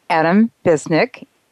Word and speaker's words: Visnic: Adam Visnic, a private investigator from Cincinnati, Ohio, joins us here to talk about this topic.